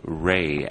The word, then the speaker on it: Rhae: And it's spelled R-H-A-E. Why Rhae as part of your chosen name?